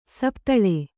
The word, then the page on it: ಸಪ್ತಲೆ: saptale